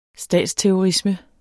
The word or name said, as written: statsterrorisme